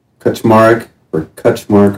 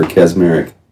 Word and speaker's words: Kaczmareck: Kuchmark or Kazmarek.